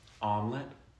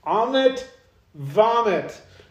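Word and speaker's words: Ammit: Omelet? – No, that's omelet. What does Amit rhyme with? What one word can you think of? – Hobbit? – Amit vomit.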